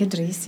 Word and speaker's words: Idris: His father actually worked for King Idris. Is that how, am I saying that right? – King Idris, yeah.